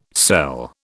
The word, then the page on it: sell: British English